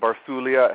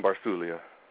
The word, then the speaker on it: Barsuglia: It's got a silent G. It's Italian. Barsuglia. It helps if you raise a hand in the air and say it like that. Brian Barsuglia.